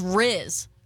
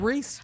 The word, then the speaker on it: Rhys: It's spelled R-H-Y-S. – R-H-Y-S? – Yes, ma'am. – Riz? – Is that Reese?